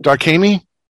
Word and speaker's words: Darkhami: Darkhami? – Darkhami.